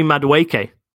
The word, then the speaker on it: Madueke: Warming up, I'm going to go with Noni Madueke.